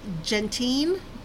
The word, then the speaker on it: Gentian: Gentiane, G-E-N-T-I-A-N. Again, spelled G as in great, E-N-T-I-A-N, like no.